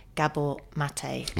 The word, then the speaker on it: Gabor Maté: That his name is spelt G-A-B-O-R-M-A-T-E, Gabor Maté. – Yeah, I've always called him Mate. – Yeah. – Gabor Mate. Yeah. – Gabor Maté, and